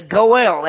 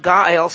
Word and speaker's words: Goel: And then we're going to see the Goel. That's a Hebrew word, G-O-E-L. Sometimes pronounced Gael. Sometimes even spelled G-O-A-L. But most time it's G-O-E-L.